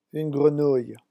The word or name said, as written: une grenouille